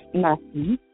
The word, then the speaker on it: Naki: Naki.